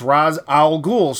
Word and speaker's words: Ra's al Ghul: Well, but it's Ra's, R-A-apostrophe-S. So it's like, oh, Ra, like the sun god. It's Ra's al Ghul.